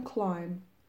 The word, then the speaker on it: climb: climb حرف ب سایلنت هست خاموشه این یعنی تلفظ نمیشه